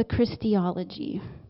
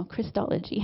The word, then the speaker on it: Christology: We call this the Christology, right? No, Christology. I said that wrong.